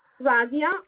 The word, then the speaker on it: Razia: This is Razia. Can you hear me? – A little bit. What did you say your name was? – Razia, R-A-Z-I-A. – R-A-Z-I-A.